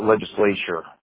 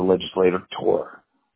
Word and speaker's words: legislator: You called him legislature, whereas he's a legislator tour. So it's a little bit different. You said like legislation instead of legislator.